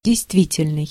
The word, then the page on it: действительный: /dʲɪjstˈvʲitʲɪlʲnɨj/